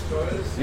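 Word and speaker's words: Gioia's: And am I saying that right? Joyous? – Joyous. – Yeah, okay, good.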